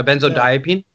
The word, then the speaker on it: benzodiazepine: Yeah, it's a benzodiazepine. Is that saying that right? A benzodiazepine? Yeah. And what a lot of people... Yeah, that's the word.